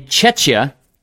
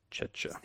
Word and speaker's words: Czechia: In Chechia. – Chechia. – C-Z-E-C-H-I-A, which Manny apparently knows how to spell that.